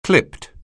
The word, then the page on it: clipped: anglais